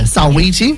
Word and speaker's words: Saweetie: Saweetie, is that how you pronounce it? – Oh, that sounds right. SAWE. – Yeah, Saweetie, which in hindsight, after writing this, she has released a song with her today.